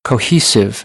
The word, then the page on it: cohesive: American English; oʊ